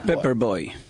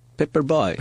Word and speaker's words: paper boy: Pepper Boy. – ¿Qué? – Pepper Boy. – Pepper. – Paper boy. – Paper. – Bueno, en algunos lugares se pronuncia. – En la escuela de. – Paper boy. – Quizás en Minnesota se dice Pepper.